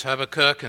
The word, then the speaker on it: Habakkuk: And I know that there are people who have learned to pronounce that in various ways. Well, however you learn to pronounce it, just say it emphatically, and it'll have impact, I'm sure. But for me, it's Habakkuk, and so I'd love for you to get your Bible set before you in the back of your Older Testament.